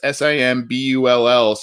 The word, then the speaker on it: SIMBULL: The address SIMBULL. So it sounds like Symbol, but it's spelled a little differently.